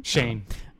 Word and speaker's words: Schoen: You are Joe Schoen. Is that how you pronounce his name? – Shane. – Is it Shane? – Mm-hmm. – That's terrible. Get out of here. Doesn't make any sense. – That is spelled E-N. – Yeah, Joe Schoen. – O-E-N? H-O-E-N. Oh, man.